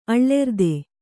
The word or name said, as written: ಅಳ್ಳೆರ್ದೆ